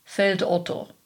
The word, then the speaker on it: Feldotto: But Germans from the central part of the country where he is from recognize this compound name for what it is, pronouncing it in a standard German fashion, Feld Otto.